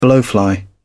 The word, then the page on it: blowfly: British English